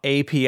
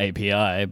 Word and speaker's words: api: How do you pronounce bees in Italian? – Api. – Happy. Okay, so it's spelled the same. – Happy. Yeah, it's the same letters, yeah.